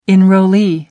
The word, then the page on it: enrollee: American English